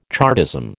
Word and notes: Chartism: American English

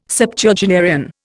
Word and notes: Septuagenarian: Indian

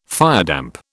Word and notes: firedamp: British English

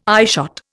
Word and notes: eyeshot: Indian

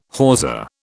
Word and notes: hawser: British English

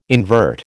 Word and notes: invert: American English

